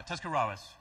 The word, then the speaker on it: Tuscarawas: Uh, Tuscarawas.